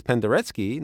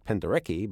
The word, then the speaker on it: Penderecki: and don't be fooled by the letters E-N-C. Francis Poulenc is Poulenc, not Poulenc. Penderecki is Penderecki, not Penderecki, but otherwise, I'm afraid when it comes to Polish and Czech, you are on your own.